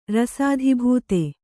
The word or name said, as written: ರಸಾಧಿಭೂತೆ